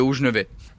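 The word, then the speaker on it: Hauts-Geneveys: Moi je dis les Hauts-Genevets.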